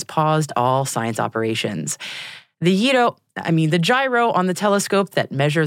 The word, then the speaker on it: gyro: Now, usually, when I'm talking to people about a gyro issue, it's actually a Yido issue. You know, the Greek cousin of a doner kebab, the pita wrap a lot of people mispronounce. But no, today it's actually properly a gyro issue, as in the gyro on the Hubble telescope.